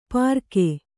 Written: ಪರಕೆ

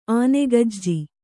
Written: ಆನೆಗಜ್ಜಿ

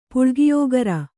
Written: ಪುಳ್ಗಿಯೋಗರ

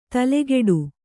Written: ತಲೆಗೆಡು

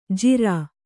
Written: ಜಿರಾ

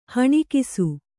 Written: ಹೊಣಕಿಸು

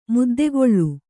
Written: ಮುದ್ದೆಗೊಳ್ಳು

